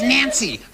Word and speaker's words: Nancy: That's Nancy. Rhymes with fancy.